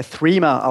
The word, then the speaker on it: Threema: Also es wird äh, Threema ausgesprochen, eigentlich wie Englisch äh, Three, einfach noch mit MA hinten dran.